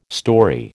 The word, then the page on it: storey: American English